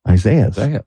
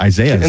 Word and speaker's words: Isaias: E-E-S-A-H-E-E. A-H-S. – And right, like, it's spelled phonetically, it's Isaias.